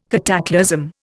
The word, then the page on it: cataclasm: Indian